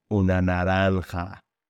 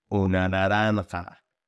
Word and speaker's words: una naranja: It's una naranja. Let's say it again together. How do you say an orange in Spanish? Really think about it. It's una naranja.